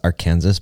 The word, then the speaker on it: Arkansas: It's pronounced Arkansas, by the way.